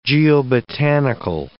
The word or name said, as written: geobotanical